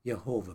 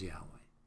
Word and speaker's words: Yahweh: Well, the word Lord in the Hebrew original language is Yahweh, or some pronounce it Jehovah, but the more accurate pronunciation is Yahweh.